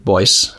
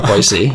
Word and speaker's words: Boise: I would have just called it Boise, but no, Boise.